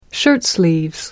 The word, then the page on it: shirtsleeves: British English